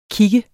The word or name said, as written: kig